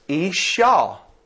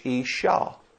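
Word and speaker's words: isha (אִשָּׁה): You could spell it in English I-Y-S-H. As some Bible dictionaries spell it. The Hebrew word for woman here is isha. You hear it. Man, woman, ish, isha.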